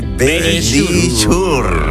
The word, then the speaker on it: Verisure: Nuestros amigos de Verisure.